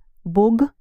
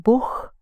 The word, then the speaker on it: Бог: Three letters, and the last letter is Г. Бог. Бог. Some people pronounce it Бог.